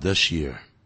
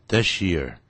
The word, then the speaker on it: this year: 就到这个月的月底，到今年年底呢？By the end of this year. This 跟 year 合在一起会念成 sh 的音，this year, this year，去年 last year 这样念啊，this year, sh, this year。